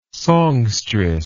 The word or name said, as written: songstress